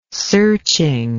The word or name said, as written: searching